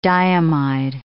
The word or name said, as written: diamide